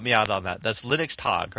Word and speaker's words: Linux Tag: Linux Tag participants. I'm pronouncing it incorrectly. It's Linux Tag, like Linux Day, but it's in German, so Linux Tag is the correct pronunciation.